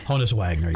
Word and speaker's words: Honus Wagner: Honus Wagner.